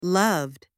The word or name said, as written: loved